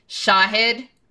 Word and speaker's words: Shahid: Shahid, so ah, the accent's on the ah, and Buttar, the accent's on the ooh.